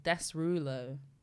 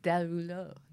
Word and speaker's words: Derulo: His name is Jason Joel Desrulo. No, but see, you just butchered it. It's Derulo. – No, there's an S. Yes, yeah, silent. That's like the S is silent.